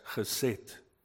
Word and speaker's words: chesed: H-E-S-E-D, pronounced as chesed.